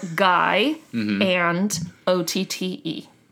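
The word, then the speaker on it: Guyandotte: So in the 1830s, residents of the community of, and I'm sure I'm going to butcher this, Guyandot or Guyandot? I don't know. It's probably completely. – I doubt it's the latter, but and you never know. – It's literally spelled Guy and O-T-T-E. Guyandot. – Guyandot? – Guyandot.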